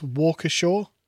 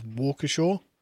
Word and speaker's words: Waukesha: Yeah, Casey says, pronounced it correctly. It's Walkershaw. – There you go. Take that. – Listen, Americans, right? – We gave you your language, first of all. – And if you're gonna write something that's spelled Waukesha, it's not pronounced Walkershaw.